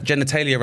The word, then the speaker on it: genitalia: Genitalia rhymes with Australia.